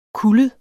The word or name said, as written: kullet